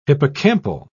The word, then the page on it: hippocampal: British English